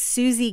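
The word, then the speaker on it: Siouxsie: Her name is Susie Gillett, and it's spelled like Susie and the Banshees. – Wow. – S-I-O-U-X.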